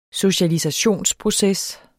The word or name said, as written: socialisationsproces